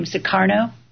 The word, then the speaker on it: Sukarno: I think you say his name, Sukarno. – Yes, that's correct.